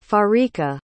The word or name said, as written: farica